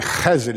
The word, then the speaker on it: Chesed: Chesed is how you say it.